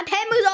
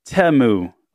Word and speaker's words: Temu: Because it's not Temu. – Temu. – Temu. – Temu. – Temu. – But it's like T-E-H, like Temu, like technology.